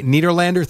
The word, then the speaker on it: Nederlander: It's called Take a Banana for the Ride at the Nederlander Theater. Am I saying that right? – Yes, legendary 103-year-old Broadway theater.